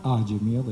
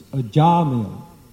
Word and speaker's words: Ajamil: It's not Ajamil, it's Ajamil. The second syllable is long.